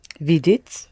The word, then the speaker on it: видеть: Видеть. – Mm-hmm. Accent the first syllable, please, again.